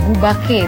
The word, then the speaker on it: Bubake: Dispel BU_BAKE, Bubake tulisannya. Tapi bacanya Bubake.